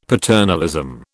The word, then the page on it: paternalism: British English